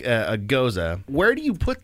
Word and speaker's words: Gose: Rosé Gose, which is actually a Goza. – Yeah, actually, it's beer. – Pronounced Goza.